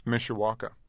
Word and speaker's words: Mishawaka: Mishawaka. – In Indiana. Is that a small mm-hmm. town or a suburb? – Yeah, it's 40,000 people. – Mm-hmm. And describe your neighborhood for us. – Mishawaka, there was like a big Uniro plant that was in the 50s that closed down, I think, in the 70s.